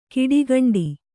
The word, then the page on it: ಕಿಡಿಗಂಡಿ: kiḍigaṇḍi